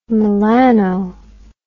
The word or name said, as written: melano